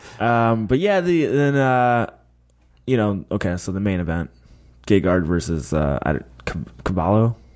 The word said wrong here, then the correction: Carvalho: We were butchering it, but we'll just go with Carvalho.